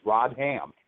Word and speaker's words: Rodham: I guess you pronounce it Rodham, you know, or but it's Rodham.